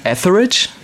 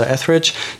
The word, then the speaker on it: Etheridge: Kannst du mir bei der Aussprache helfen? Etheridge? – Ja, richtig. Melissa Etheridge.